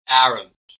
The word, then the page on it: arrant: American English